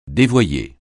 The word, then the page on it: dévoyer: francais